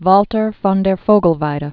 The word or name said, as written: Walther von der Vogelweide